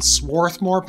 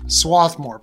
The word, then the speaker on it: Swarthmore: So I live in Swarthmore, Pennsylvania, or Swarthmore, Pennsylvania. There's either an R there or not.